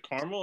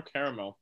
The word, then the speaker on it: caramel: Is it caramel or caramel? – It's spelled like caramel.